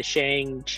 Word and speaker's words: Shang-Chi: But then you get the Shang-Chi, did I say that right? – Yeah.